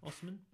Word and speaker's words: Osimhen: how do you pronounce it? Osimhen? – Yeah, Osimhen.